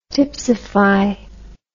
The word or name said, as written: tipsify